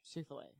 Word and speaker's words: Chick-fil-A: Chick-fil-A. That's how it's pronounced.